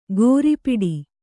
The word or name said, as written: ಕೊರಿಪಿಡಿ